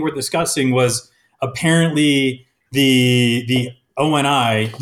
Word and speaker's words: ONI: It is definitely pronounced O-N-I because in that prologue scene when Halliday is like giving him the headset, an O-N-I headset can also be used to record its wearer's experiences in the real world.